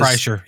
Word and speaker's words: Kreischer: And the show was sort of featured, kind of put together by, I don't really know how you pronounce his name, Bert Kreischer. He's the guy that does Kreischer.